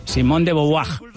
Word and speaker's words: Simone de Beauvoir: Pronunciado en francés, Simone de Beauvoir.